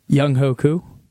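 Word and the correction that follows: Younghoe Koo: So that is incorrect. It's Young Waku.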